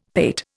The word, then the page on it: pate: Indian